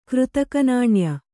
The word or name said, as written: ಕೃತಕನಾಣ್ಯ